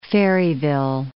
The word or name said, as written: Ferryville